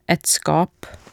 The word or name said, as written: et skap